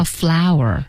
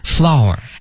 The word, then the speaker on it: flour: 面粉这词儿我最喜欢，因为它那读音吧，跟那花儿一样，叫 flower。 – flower。 – F L O U R。